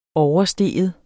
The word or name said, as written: overstige